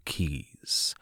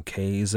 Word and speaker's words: Cays: The Dutch called them Cay, and the English anglicized it to Keys. All of which is to say, if I slip up and call them Cays at some point, don't blame me. I'm just following older pronunciations.